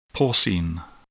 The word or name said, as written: porcine